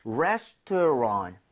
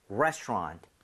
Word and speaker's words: restaurant: 레스토랑, 레스토랑. 여기 RANT를 랑트도 아니고 랑도 아니라 살짝 런에 가깝게 하면 발음이 조금 더 괜찮습니다.